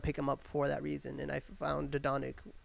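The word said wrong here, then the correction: Luka Dončić: Luka Doncic.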